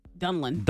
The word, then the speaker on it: Donelan: Donalan, is that her name? I had a Donilan. Donilan? – Who? – D-O-N-E-L-A-N. How would you say that name? – Donalan? – Dunlan? – Dunlan.